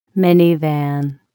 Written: minivan

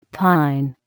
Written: pine

